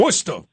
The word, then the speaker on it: Worcester: And pardon me with my Midwest accent if I'm not pronouncing Worcester correctly. – Worcester. – But, there you go. Thank you. Help a brother out.